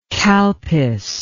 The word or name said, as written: kalpis